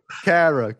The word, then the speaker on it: Kehrer: Kehrer. Go on. – You got it right. I'm pretty sure you got it right. – Oh. Well, if we look at Tilo Kehrer,